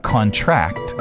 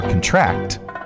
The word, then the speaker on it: contract: Number two, when a word has multiple meanings, in virtually all cases, the noun form stresses the first syllable. The verb stresses the last. For example, contract is a noun, or contract is a noun, or contract is a verb.